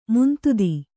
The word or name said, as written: ಮಂದತೆ